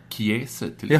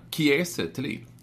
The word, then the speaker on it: Kiese Thelin: Man ska uttala det Kiese Thelin. – Jaha, okej, okay, jag har sagt Kiese. – Inte Kiese Thelin, utan Kiese Thelin.